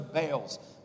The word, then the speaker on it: Baal: And the people of Israel did what was evil in the sight of the Lord and served the Baals. By the way, it's actually pronounced Baal, and those are false gods.